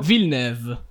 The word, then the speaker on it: Villeneuve: aspetta, come l'hai pronunciato? – Villeneuve. – Si pronuncia così?